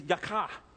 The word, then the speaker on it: yakah: And this word confront in the Hebrew is yakah. There you go. That was a good Hebrew pronunciation. A little saliva in there.